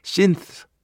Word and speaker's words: synth: 그렇기 때문에 우리라도 정확한 표현으로 신스 아니면은 s y n t h 이렇게 발음을 하도록 해야 하는 것이다.